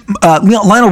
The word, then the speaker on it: Lionel: Lionel Richie says that Michael Jackson repeatedly called him Lionel, like the trains I grew up with, the Lionel trains, when everyone else called him Lionel Richie. He said, Michael called me Lionel. He's like it's from Krypton, like Kal-El and Jor-El and Lionel. Okay, that's how Michael pronounced Lionel.